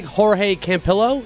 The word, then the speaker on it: Jorge Campillo: In Spain, it sounds like Jorge Campillo. Did I say that right, Gabe? – Yes.